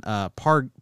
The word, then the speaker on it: Pargin: It's Jonathan or Jason Pargin, Pargin.